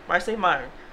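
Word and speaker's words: Marsai Martin: I want to pronounce it correctly, but Marcy Martin. M-A-R-S-A-I. Marcy Martin.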